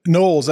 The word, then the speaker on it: Knowles: Knowles, I always say, just like Beyonce, you know, my sister, obviously.